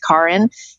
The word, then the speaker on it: Karin: My name is actually pronounced Karin. You know, I've moved my middle name to my last name, but my name in German is Karin Luise.